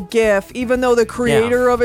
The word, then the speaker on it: GIF: just got a gift sent to me.